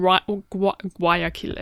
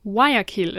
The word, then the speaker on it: Guayaquil: I flew from Ecuador, from Guayaquil, actually. That's not how you say it. Guayaquil is how you say it.